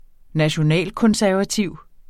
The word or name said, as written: nationalkonservativ